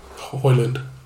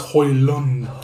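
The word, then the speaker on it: Højlund: Instead of paying $67 million for Hoyland. – Yeah. – In fact, I said that wrong. It's Hoyland.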